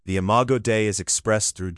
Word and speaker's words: Imago Dei: It's a hardware feature of the Imago Dei, pronounced Imago Dei.